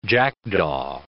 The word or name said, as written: jackdaw